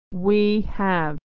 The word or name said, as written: we have